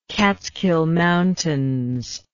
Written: Catskill Mountains

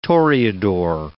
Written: toreador

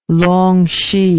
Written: Longxi

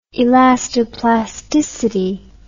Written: elastoplasticity

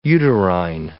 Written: uterine

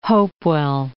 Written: Hopewell

